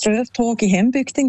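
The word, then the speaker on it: Strövtåg i hembygden: Ich finde, du hast die Aussprache super hergebracht. Das Lied heisst Ströftag in Hembügden, genau.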